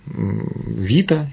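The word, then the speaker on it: вита (βήτα): В разных прочтениях вот это бета, она читается как вита, поэтому алфавит.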